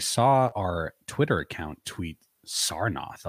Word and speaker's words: Sarnath: Real question, is it Zarnoth or Sarnoth? – Sar with the S.